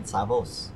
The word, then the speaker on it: Savos: So I created Savos. – That's how you, uh, you pronounce it.